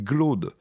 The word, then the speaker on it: Claude: Ainsi, on disait non pas « Claude » mais « Glaude », tout en l'écrivant avec un C, à la manière de « second », mot dans lequel aujourd'hui le C reste toujours prononcé « G ».